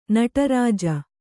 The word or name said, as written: ನಟರಾಜ